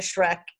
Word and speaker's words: Schreck: Just at Gina Schreck. And I always say it is Schreck like the movie, but it's spelled with C's instead, S-C-H-R-E-C-K.